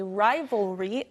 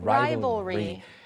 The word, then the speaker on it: rivalry: 우리도 그냥 라이벌, 경쟁 상대라고 말하는데 rival 뒤에 L로 끝나지만 R, Y를 붙여서 발음이 좀 어려울 수 있어요. – Rivalry.